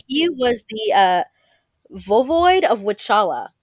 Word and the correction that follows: Wallachia: Wallachia. I'm sorry. I call it Wichala because that's how it's spelled. Like it looks like in English, but it's called Wallachia.